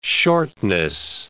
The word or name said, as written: shortness